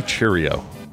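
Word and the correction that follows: Chourio: Jackson Churio. – Churio.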